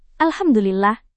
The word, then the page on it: alhamdulillah: indonesian